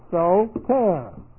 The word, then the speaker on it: soter: But in the New Testament, we have so ter. Looks like this. S, long O, T, long E, R. Soter.